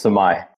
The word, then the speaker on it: Sumay: You're close to it. It's Sumay.